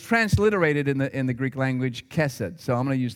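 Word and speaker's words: chesed (חֶסֶד): Now, in Hebrew, that is a word that is pronounced chesed.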